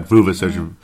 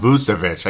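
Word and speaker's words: Vučević: I've always liked, not Vucevic, Vucevic. I keep saying his name wrong, but Nikola Vucevic, the better Nikola, in my humble opinion, didn't have a very particularly high field goal percentage.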